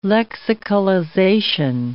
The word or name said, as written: lexicalization